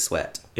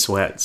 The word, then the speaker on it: Sweat: And I've been saying it wrong all these times, but it's sweat. It is sweat. Somebody asked me on Facebook recently, like on a post, and I was like, well, how do you describe? So finally I came up, it rhymes with jet.